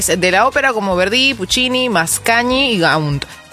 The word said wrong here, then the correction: Gounod: ¿Cómo se dice? Gounod, ¿cierto? ¿Uno está bien dicho? – Sí.